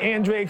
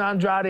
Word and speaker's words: Andrade: At the end of the day, in this fight, you know, last fight I said pronounce it Andre because I was going against Demar Nicholson. I was like, yeah, man, just say Andre. – Yes. – Now I think Benavides and Andrade is the way to pronounce this fight.